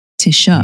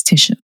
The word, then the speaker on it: Tisha: Okay, so technically is Tish, Tisha. Mm-hmm. That's what my family calls me, it's Tisha.